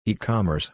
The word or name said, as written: ecommerce